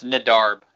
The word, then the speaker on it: Nedarb: Like, I mean, any Peep collab with Net, is it Nedarb? Am I butchering that? – It's Brandon backwards, yeah. – It's Nedarb is how Nedarb. I always pronounced it.